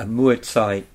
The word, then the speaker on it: Mui Zai: So tell us what a Mui Zai is. – I will do. – Good pronunciation, too. – That's a great pronunciation, yeah.